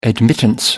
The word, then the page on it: admittance: American English